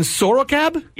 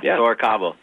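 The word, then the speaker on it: Sorocaba: Is it Fernando and Sorocab? – Yeah. Sorocabo.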